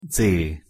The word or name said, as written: dz